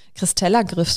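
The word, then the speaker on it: Kristeller-Griff: Aber ich wurde immer gefragt und ich hatte auch einen leichten Christella-Griff sogar. Und äh, habe ich das richtig ausgesprochen? Ja. Gut. Ähm,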